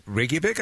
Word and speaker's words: Reykjavik: Did you all meet, was it Reykjavik, I guess? Am I pronouncing that correctly, by the way? – Yeah. It's a small town right outside of Reykjavik.